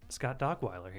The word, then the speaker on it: Dockweiler: I have my friend Scott Dogweiler here. – Hello. – That is how you say your last name, right? – It is, yes.